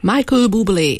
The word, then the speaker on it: Michael Bublé: A kiejtésére esetleg bocsánatot kérek, Michel Bublé. – Michael Bublé énekelt az elmúlt percekben, valóban 2011-es Christmas című albumáról idéztük ezt a dalt Mikulás napján.